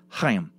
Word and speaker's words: Chaim: Chaim Weitzman's first name and mine is pronounced Chaim, with a sort of throat-clearing sound at the beginning.